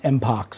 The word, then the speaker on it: mpox: The WHO, as per the World Health Organization, has proposed just to call it MPX, which is going to be renamed, pronounced MPOX.